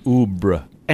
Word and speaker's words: Oubre: Because Oob is spelled O-U-B-R-E. Oobre.